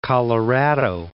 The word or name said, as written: Colorado